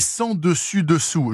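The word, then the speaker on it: sens dessus dessous: Et sans dessus dessous, je précise, hein, dont le S de sang ne se prononce pas.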